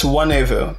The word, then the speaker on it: Nwanevu: Not n- the only difference is the N is silent. It's Wanevu, but you, you're much closer than many people get.